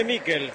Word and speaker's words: Miquel: ¿Cómo le decimos? ¿Michael o Miquel? Nicolás Álvarez, usted que es especialista en nombre, se escribe Miquel, ¿cómo le digo? – Decile Miquel. – Entonces le digo Miquel, usted me enseña y yo aprendo.